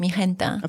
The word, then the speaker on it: Mijenta: Now, your brand is Mijenta? – It's pronounced Mijenta. – Of course it is. Put me right in my place. – Actually, Mijenta, it's an inspiration behind the Spanish words mi gente, my people, so my group of friends.